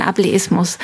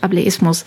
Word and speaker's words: Ableismus: Ableism, also auf Englisch ausgesprochen oder eher auf Deutsch Ableismus? Ich muss nämlich gestehen, manchmal bin ich auch selber befangen und versuche Begriffe nicht zu verwenden, um sie eben nicht falsch zu verwenden. – Ich höre tatsächlich beide Aussprachen.